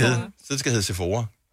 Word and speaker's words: Sephora: Og det var først der, min hjerne har aldrig nogensinde brugt tid på at skulle spekulere over, hvordan udtales S-E-P-H-O-R-A. Sephora, Sephora, I don't know. Men det var selvfølgelig Sephora.